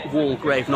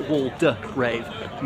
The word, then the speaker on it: Waldegrave: I think it's better be pronounced Walgrave, not Waldegrave. Methinks the middle syllable is silent, as he was a cabinet minister under Maggie Thatcher.